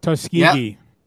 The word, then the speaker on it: Tuskegee: Is Tuskegee? Is that how you pronounce it? – Yeah, Tuskegee. – Yeah. It was close.